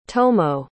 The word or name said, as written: tomoe